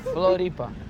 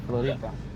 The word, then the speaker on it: Floripa: Floripa. – That's it. End of it. Then. We in Floripa. – We're in Floripa. – No, Floripa. – We're in Floripa. – Floripa. – There you go, Floripa.